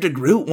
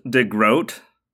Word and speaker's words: DeGroot: "I think Mr. DeGroot wanted to talk to me." She was puzzled. I looked at her nameplate. Ingrid. – "It's pronounced DeGroat. It rhymes with boat."